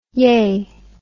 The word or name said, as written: yay